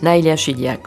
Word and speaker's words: Naïla Chidiac: C'est comme ça qu'on prononce ? – Naïla Chidiac, oui.